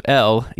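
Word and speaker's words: El: But yeah, so apparently is the house of L, E-L, pronounced like the letter L, looks like an S, which is a coincidence.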